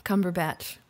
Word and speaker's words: Cumberbatch: Say just his last name. – Cumberbatch. – Mm-hmm. Now say it a little bit differently. – Cumberdatch. Cumberdatch. – Close, but yeah, it's that last syllable, that last thing. What's batch? What's another way you could say batch? – Cumber.